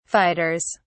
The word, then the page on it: fighters: British English